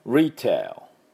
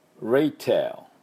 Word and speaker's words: retail: Retail Retail、ね、これも発音しましょう。 R ですね。で、しっかりね、 T の音を出して tail、 A です。 A で L で終わります。 tail って言わないよ。